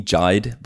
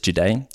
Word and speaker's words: Jade: Everyone calls me Jide because it's spelt like Jide, J-I-D-E, but it's Jade.